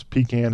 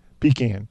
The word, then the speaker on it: pecan: So, this poll, which is in my native state, by the way, shows that 57% of the people pronounce pecan as P-E-E-C-A-N, pecan, not pecan.